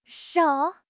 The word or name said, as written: shou3